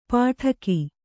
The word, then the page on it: ಪಟಕಿ: paṭaki